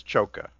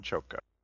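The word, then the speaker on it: Csokas: And Martin and by the way, I looked this up. It's Choka, Martin Choka.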